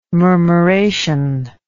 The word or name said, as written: murmuration